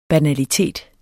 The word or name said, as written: banalitet